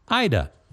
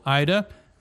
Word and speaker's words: Ada: This is from Ms. Harrison, whose name is spelled A-D-A, but you pronounce it like Ida. So, Ida,